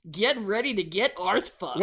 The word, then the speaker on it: Arth: Tom Arth.